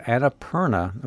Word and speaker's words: Annapurna: And let's look at the first show you've got coming up, which is a play called Annapurna. Am I pronouncing that correctly? – Yes, you are.